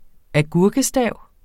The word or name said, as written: agurkestav